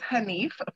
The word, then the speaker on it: Hanif: My last name is Hanif, for those who know.